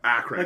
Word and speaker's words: Akron: Akron. Like Ohio. Akron, Ohio. Yeah.